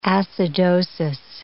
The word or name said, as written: acidosis